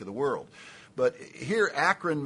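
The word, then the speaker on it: akron (ἄκρον): The word end is akron, spelled and pronounced like the city 5 miles away.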